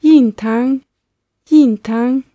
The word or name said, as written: yintang